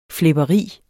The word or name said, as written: flæberi